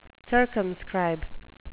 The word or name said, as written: circumscribe